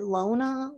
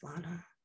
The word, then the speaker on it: Lana: It's like, is it Lona? Lana? I'm like, it's just Lana. It's Lana. And they're like, oh, Lana.